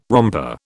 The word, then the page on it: romper: British English